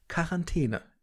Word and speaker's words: Quarantäne: Es ist tatsächlich so, dass das nur mit K ausgesprochen wird. Also Quarantäne. Ich habe es also falsch gemacht.